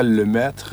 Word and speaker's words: Lemaitre: Yo, lastimosamente, nunca aprendí el francés, pero sí, se pronuncia Lemaitre en la forma original, pero ya me han dicho Limache, Lemaitre, Limetre, no importa.